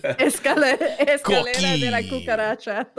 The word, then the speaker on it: escalera: What's Escuela, whatever. – Escalera.